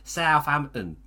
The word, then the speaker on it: Southampton: Christ is watching as Hayter from Southampton. That's South as in Alf. That's how you say it. Southampton.